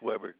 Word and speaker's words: Weber: Thomas Weber. – You say your last name was Weber, W-E-B-E-R.